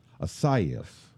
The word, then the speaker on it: Isaias: There's the traditional Spanish pronunciation sounds more like Isasius. – Isasius. – Isaias. I say I'm not doing it right. Isaias.